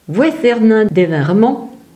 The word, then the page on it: Vouéthèrnens-devant-Remont: vwɛθɛʁˈnɛ̃ deˈvɛ̃ ʁəˈmɔ̃